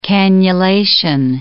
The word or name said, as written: cannulation